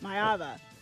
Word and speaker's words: Maiava: A college football player has flipped his commitment, and so Jayden Maiava, believe I was saying that right. I pulled up the pronunciation, and now I think I butchered it because I was overthinking it before Maiava. – I said it. Maiava. He is now heading to USC, not to Georgia, his father says.